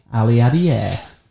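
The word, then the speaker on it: Aliadière: It's called Who Wants to Be Jeremy Aliadier? – Very good. – Rhymes with millionaire.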